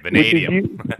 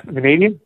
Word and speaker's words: vanadium: Vanadium. – Vanadium, yep.